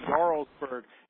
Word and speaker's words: Jarlsberg: It's spelled with a J, but it's called Jarlsberg.